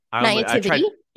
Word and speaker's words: naivety: Volob says Denise's naivete. Man, naivety. – Yes. Okay. So every time I read that word, I pronounce it wrong, even though I know what it is I'm saying.